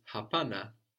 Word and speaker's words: hapana: The word for no is hapana, hapana. – Hapana. – Which is strange, three syllables for no, no?